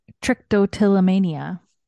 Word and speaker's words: trichotillomania: She admits she has, how do you say this, trichotillomania? – Trichotillomania.